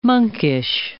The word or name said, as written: monkish